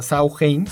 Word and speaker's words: Samhain: se pronuncia Sauhain,